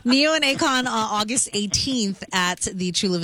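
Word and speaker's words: Ne-Yo and Akon: Do you want to go see Neo and Akon? Or, did I say that right? – Yeah, Neo and Akon.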